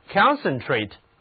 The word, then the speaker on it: concentrate: 如果给词根 center 加上表示共同的前缀 con 以及动词后缀 ate，读作 concentrate，这个词的重音放在第一个音节上。